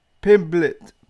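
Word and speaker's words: Pimblett: How do you say his name? Patty the Batty. It's either Pittman or Pin. Let me see. Patty the Baddie Pimblet. Pimblet.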